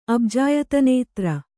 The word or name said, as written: ಅಬ್ಜಾಯತನೇತ್ರ